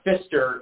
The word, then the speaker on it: Pfister: Fister is actually spelled with a silent P on the front, so it's P F I S T E R Hotel, and it is still there in uh, Milwaukee.